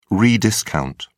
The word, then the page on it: rediscount: British English